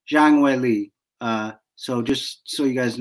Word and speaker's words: Zhang Weili: The Chinese pronunciation would be Zhang Weili.